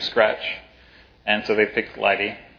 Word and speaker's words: lighttpd: So basically, someone had an open source project, and that project was called LIGHTD, L-I-G-H-T-T-P-D, pronounced LIGHTD.